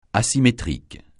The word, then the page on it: asymétrique: francais